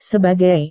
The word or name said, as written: sebagai